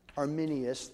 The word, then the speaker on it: Arminius: And they spent 12 years in Holland, and things were going smoothly until a man named Arminius, I've got that pronunciation wrong, let me get his name here, Arminius, thank you, Arminius began to teach a different gospel.